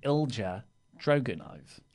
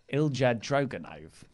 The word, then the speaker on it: Ilya Druganov: Speaking of that accent, the mayor of Painesville emailed in with a 2-minute video instructing Laurie on how to say Ilya Druganov, because I think he said it wrong in yesterday's news. – It's Ilja Droganov. Ilja Droganov.